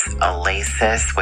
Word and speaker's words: Alasis: Her name is Alasis, which is A-L-A-S-I-S. We think of it as Alasis sometimes, but you have to pronounce it, you know, with the A part, basically. She